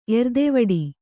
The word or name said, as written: ಎರ್ದೆವಡಿ